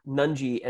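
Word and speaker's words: Nunge: Their leading scorer is Jack Nungy, if I'm saying that correctly. – Nungy, at about 13.3 points a game.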